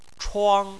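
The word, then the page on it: chuang1: tone 1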